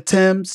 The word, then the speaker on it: Tems: That singer-songwriter, while I didn't have any problem with Angela Bassett, that singer-songwriter, Thames, T-E-M-S is how they pronounce the name, made a bold fashion statement at the 95th Academy Awards, the Oscars in Hollywood last Sunday.